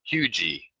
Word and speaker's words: Huger: There's a town and a street down here, spelled H-U-G-E-R, and I have been pronouncing it Huger, but that's how you stand out as not being a local, because the locals call that Hugie. – Wait, how they say Hugie and not Huger? – Then there's no R on it. It's huge E. For the locals, there's no R. Even though it's spelt with an R, they don't pronounce the R.